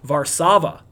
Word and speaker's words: Warszawa: You say it or you pronounce it as Warsaw in English, but it's actually Warszawa in Polish.